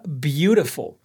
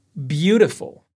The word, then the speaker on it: beautiful: O flap T e o flap D são características marcantes do spoken American English, inglês americano falado é um fenômeno que acontece quando o T ou o D aparece entre vogais e a sílaba não é tônica. Um exemplo que podemos retirar da aula é a palavra beautiful, bonita, bonito em português. Repete aí: beautiful.